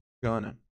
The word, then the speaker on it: gonna: Bom, importa aqui a nossa pronúncia, que é going to, going to, e vai virar gonna.